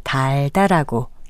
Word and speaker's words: 달다: 달다라고 길게 발음합니다.